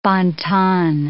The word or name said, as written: bon ton